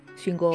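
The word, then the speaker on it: Shingō: Esta leyenda, los, los lugareños del lugar, que es un pueblo que se llama, se llama Shingo, pero ¿cómo se pronuncia esa O que tiene como un guión arriba? Doble O. – Shingo.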